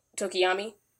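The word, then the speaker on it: Tokoyami: Because that's the one with Tokiyami.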